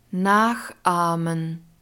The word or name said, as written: nachahmen